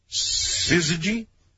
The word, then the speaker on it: syzygy: Oh, it sounds like there's an R in there. – Well, it shouldn't. Syzygy.